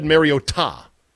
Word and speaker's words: Mariota: Yes, I said Mariota, because that's apparently the correct way to pronounce his name.